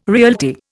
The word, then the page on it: realty: Indian